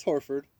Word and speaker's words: Horford: I think you said it right the first time. – I think I said, Horford. Is it Horford? – No, just Horford.